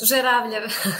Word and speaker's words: Zeravljev: Zeravljev.